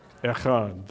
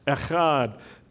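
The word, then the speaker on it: Echad (אחד): How do you say one in Hebrew? Echad. That's right. Extra donuts for you. Echad.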